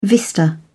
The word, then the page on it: vista: British English